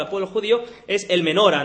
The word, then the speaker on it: menorá: En hebreo, la palabra que está aquí usada por candelero es la palabra menora, o más bien dicho, la pronunciación más correcta es menorá.